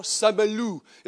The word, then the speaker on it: Saint-Malo: And we decided we were going to go to this seaside town called Saint-Malo. Saint-Malo, I'm going to Saint-Malo. Saint-Malo is how the French pronounce it.